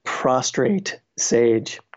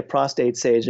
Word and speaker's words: prostrate sage: And underneath that, there's a black sage, a prostrate sage. I always say that wrong. I say a prostate sage, and then people look at me cockeyed. Prostrate sage,